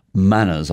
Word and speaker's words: MANRS: And you've got four main priorities, according to your website: community networks, internet governance, the Internet of Things, and manners, I imagine it's pronounced, M-A-N-R-S, mutually agreed norms for routing security, or routing security, as you say, in North America.